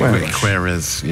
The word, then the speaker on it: Queiroz: I never know how to pronounce his name. Carlos? – Queiroz, is it? Queiroz, yeah.